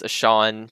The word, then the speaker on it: Ahcan: Also, the fact that it's pronounced Ashawn just messes with my brain so much.